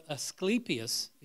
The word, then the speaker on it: Asclepius: So people from the whole empire would come because they had this god Asclepius, as it's pronounced, is the healing god.